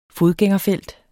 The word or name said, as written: fodgængerfelt